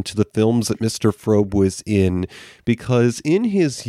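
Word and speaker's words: Fröbe: Well, the actress' name was Gert, and I want to say the pronunciation is Frobe. It's one of those European names where the O has the dots over it, the umlaut.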